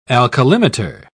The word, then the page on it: alkalimeter: British English